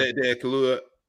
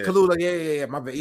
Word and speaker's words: Kaluuya: Kaluuya. Yeah. – Kaluuya.